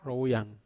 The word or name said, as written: rowuyang